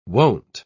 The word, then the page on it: won't: British English